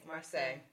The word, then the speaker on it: Marsai: Marseille.